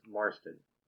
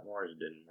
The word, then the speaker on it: Marston: Nick's Marston. Not Marsden. Marston. Not like James Marston. – But Marston. Like Nick Marston. – Yeah. – Like Mars 10. That's right. How it's spelled.